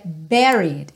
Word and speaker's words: buried: Aí agora aqui uma super pegadinha, porque a gente tem essa palavra com U, que é o enterrado, mas a pronúncia dela é buried. It's buried in my soul. Não é bu nem bu, ok?